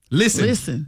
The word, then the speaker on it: listen: Listen is another one where the English words has a silent letter. – Silent and listen. – T. Listen. You don't hit no T in listen.